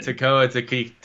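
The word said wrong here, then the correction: Taika Waititi: Taika, like, you're right there. – Waititi.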